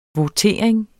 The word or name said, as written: votering